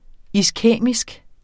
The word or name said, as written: iskæmisk